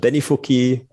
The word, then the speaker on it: Benifuki: Habe ich es richtig ausgesprochen oder war es ganz falsch? Benifuki, Pulver, äh, Ichibancha.